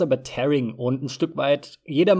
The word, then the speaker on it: tearing: Äh, Tears sind die Tränen und to tear heißt zerreißen und genau das passiert ja mit dem Bild, das ist Tearing.